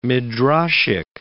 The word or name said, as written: midrashic